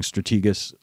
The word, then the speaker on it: Strategas: Tell us how you end up establishing Strategus Securities.